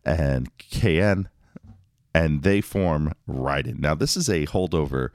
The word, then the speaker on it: Raiden: And just going off of that commercial, if you're ever wondering the proper way to say it, is it Raiden or Raiden? The commercial does say Raiden.